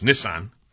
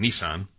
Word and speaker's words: Nissan: I met with Nissan, and it's actually pronounced Nissan, but everyone here, we call it Nissan.